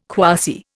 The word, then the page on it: quasi: Indian